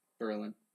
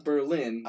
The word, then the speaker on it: Berlin: Berlin. Not Berlin, that's Germany. – Right. – Berlin is the new. – It used to be Berlin. Did you know that? Before World War II, it was pronounced Berlin.